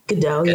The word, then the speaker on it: Gadot: Oh, Gal Gadot, the Wonder. Am I saying that right? Wonder Woman. – She be yeah, Gal Gadot. – Gadot.